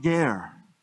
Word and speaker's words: ger: It's a Hebrew word, and it's pronounced ger.